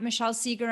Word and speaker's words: Michelle Segar: I'm at Michelle Segar